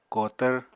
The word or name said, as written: kotvr